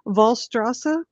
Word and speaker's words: Wallstrasse: And can you tell us a little more about 16, if I say this right, Wallstrasse? – Perfect. Well done.